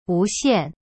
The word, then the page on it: 无线: wu xian